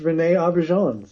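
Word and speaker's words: René Auberjonois: It's René Auberjonnes.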